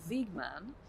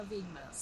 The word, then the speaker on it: Wiegman: Jag hör att du säger Wiegman med ett nederländskt uttal. Jag tänker inte ge mig på det. Jag håller mig till Wiegman. Serena Wiegman.